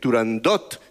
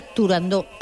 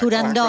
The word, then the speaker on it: Turandot: pues no había que decir Turandot, sino que, a pesar de que tuviera una T...